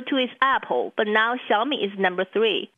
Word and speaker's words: Xiaomi: If you want to introduce Americans to the world's new third biggest smartphone maker, you got to master its name. – I like to pronounce it Xiaomi. – Xiaomi.